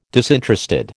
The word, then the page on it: disinterested: American English